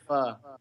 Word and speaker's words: pho: Oh, did I pronounce it wrong? – Yeah, it's pronounced pho.